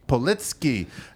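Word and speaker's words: Paulitzki: Politsky.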